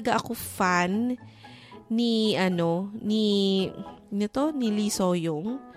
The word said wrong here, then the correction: Se-young: Se-young. Tama ba? Mali na naman yung pagbigkas ko. Lee Se-young.